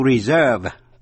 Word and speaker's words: reserve: Cũng để ý đến cách phát âm động từ to reserve, tức là dành chỗ, to reserve, nhưng mà danh từ reservation. Quý vị nghe lại, to reserve, reservation.